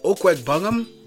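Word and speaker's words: Okwuegbunam: I believe his name is pronounced Okwegbungam.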